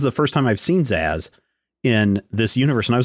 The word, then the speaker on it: Zsasz: Zsasz, I believe is pronounced.